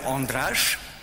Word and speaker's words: Andrzej: Je m'appelle Andrzej. C'est la bonne prononciation de mon prénom. C'est comme André, mais c'est meilleur.